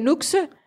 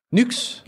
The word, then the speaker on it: Nuxe: Og den her, det er N-U-X-E. Det er nyks.